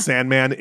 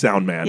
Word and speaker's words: Soundman: It's not Sandman, it's Soundman.